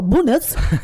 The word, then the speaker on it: Bones: So we stayed in that place was called Bones, well, it's spelt bones, but they, when we said we stayed there, some Norwegian was like, oh, bonus. And I was like, oh, right, okay, we've been saying it wrong.